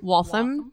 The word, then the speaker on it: Waltham: Waltham. And it's W-A-L-T-H-A-M. Mm. And I always want to overpronounce Waltham. – It. And be like, Waltham.